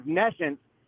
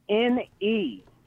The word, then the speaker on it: nescience: So there's ignorance where you act like you don't know something, but then there's nescience where you actually don't know something.